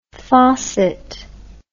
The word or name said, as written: fawcett